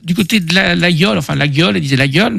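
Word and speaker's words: Laguiole: Nous sommes allés aussi passer une semaine à Laguiol, pas la gueule, hein. On prononce l'aïeul pour les autres.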